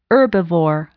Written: herbivore

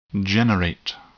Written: generate

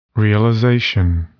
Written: realization

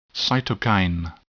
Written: cytokine

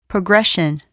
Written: progression

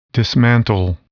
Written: dismantle

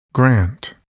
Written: grant

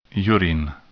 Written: urine

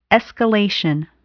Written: escalation

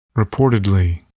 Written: reportedly

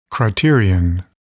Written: criterion

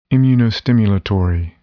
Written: immunostimulatory

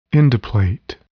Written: endplate

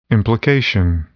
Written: implication

